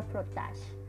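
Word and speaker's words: frottage: Se llama frotage Es una palabra en idioma francés y significa frotar. Se pronuncia frotage.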